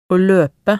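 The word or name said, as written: å løpe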